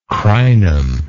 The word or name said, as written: crinum